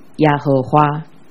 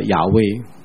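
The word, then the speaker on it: 雅威: 伊的本体还有一个名，古有差不多 7000次讲到一个名，就是耶和华。 – 现在的学者认为应该回到他最初犹太人给这个神的名字的读音，叫做亚威。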